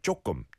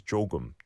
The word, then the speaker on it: 조금: I often hear, and like I said, I pronounced it like 조금, 조금. You would make it almost like a 쌍지엇, but the actual dictionary way and the way you're meant to pronounce it is 조금, 조금, 조금, okay?